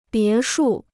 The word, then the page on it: 别墅: bié shù